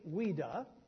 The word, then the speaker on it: Ouida: The Reverend Sobey Ouida, O-U-I-D-A, is how you pronounce the last name, impressed us all as a godly and faithful man and pastor.